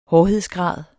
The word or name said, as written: hårdhedsgrad